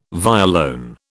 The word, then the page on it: violone: British English